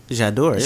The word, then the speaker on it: J'adore: He gave her a perfume named, I hope I said this right, J'adore. – J'adore, yeah. – J'adore. Yeah, so, and she loved it one too, so I know I have another option now. – I feel like I had J'adore when I was like 18.